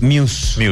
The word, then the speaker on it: Muse: Yo dije Mouse, porque leí mal, pero era Muse. – Es Muse. Muse es la pronunciación oficial.